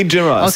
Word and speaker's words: Gerrard: Ich hätte ihn jetzt Garrett ausgesprochen. – Nee, Gerrard. Steven Gerrard.